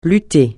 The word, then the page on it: lutter: francais